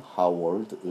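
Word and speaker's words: Howard: 특히 합격생의 평균 성적이 유별나게 낮으므로 쉽게 관심을 갖게 되는 하워드 의대나 하버드하고 스펠링이 조금 비슷합니다. H O W A R D라는 하워드 의대 혹은 메헤리 의대 이두대는 전통적으로 흑인 학생들을 선발해서 흑인 사회를 위한 의사로 양성하고자 하는 설립 목적을 갖고 있는 학교라는 사실을 참고해야겠습니다.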